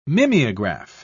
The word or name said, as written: mimeograph